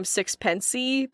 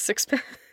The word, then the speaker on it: Sixpence: Because anyways, Sixpence, which I also always pronounced her name Sixpency because there were like five E's afterwards. So I'm glad that you set me straight that it's Sixpence.